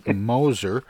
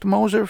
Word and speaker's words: Moser: It's Phil Moser. Is it pronounced Moser, Phil, or is it Mosier? – Yeah, Moser.